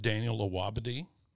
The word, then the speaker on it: Daniel Awabdy: We're joined today with Daniel Awabdy.